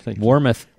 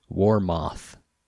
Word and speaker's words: Warmoth: You know, I used to have a customer in Seattle who would order stuff from Warmouth, and he always pronounced it Warmoth. – Okay. – Moth. Like a... – Yeah. – The flying insect. – Well, is it O-T-H or is it O-U-T-H? I don't remember. – It's O-T-H.